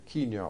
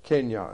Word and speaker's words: Kenya: Later, very much later, the title was changed to the Kenya, or Kenya, as it is correctly pronounced in the vernacular, Armored Car Regiment, and that's what it remained known as, KACR.